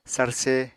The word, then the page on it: sarse: apatani